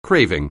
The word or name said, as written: craving